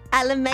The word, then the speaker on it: Alameda: It is at 777 South, how do you say that? – Alameda.